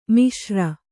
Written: ಮಿಶ್ರ

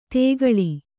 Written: ತೆಗಳಿ

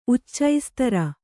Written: ಉಚ್ಚೈಸ್ತರ

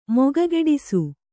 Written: ಮೊಗಗೆಡಿಸು

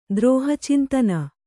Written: ದ್ರೋಹಚಿಂತನ

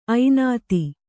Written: ಐನಾತಿ

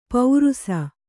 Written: ಪೌರುಸ

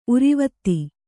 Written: ಉರಿವತ್ತಿ

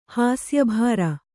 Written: ಹಾಸ್ಯಭಾರ

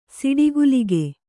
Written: ಸಿಡಿಗುಲಿಗೆ

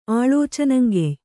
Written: ಆಳೋಚನಂಗೆಯ್

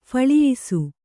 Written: ಫಳಿಯಿಸು

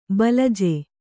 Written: ಬಲಜ

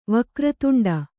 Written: ವಕ್ರತುಂಡ

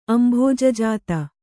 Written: ಅಂಭೋಜಜಾತ

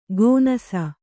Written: ಗೋನಾಸ

